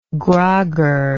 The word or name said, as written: grogger